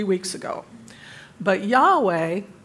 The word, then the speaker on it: Yahweh: We pronounce that word Yahweh, although we don't know how it was pronounced originally.